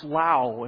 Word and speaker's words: Lowell: I believe actually it's spelt Lowell. It's pronounced Lau. And so if you want to impress them, say hi, Mr. and Mrs. Lau.